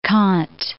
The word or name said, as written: Kant